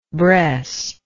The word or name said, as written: bres